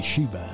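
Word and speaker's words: Síomha: si scrive in tutt'altro modo, ma abbiamo appurato che la pronuncia di questa splendida voce, la pronuncia del suo nome si intende, è Shiva. La trovate però con la scritta S-I-O-M-H.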